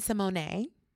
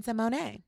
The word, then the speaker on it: Symoné: It's Raven Symoné. – Because she corrected us. – Absolutely. – In the most beautiful way and gave us the correct pronunciation of her name. – Yes, and it's Symoné.